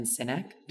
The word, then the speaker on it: Sinek: By Simon Sinek. Did I say that wrong? – No, that's right.